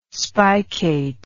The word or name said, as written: spicate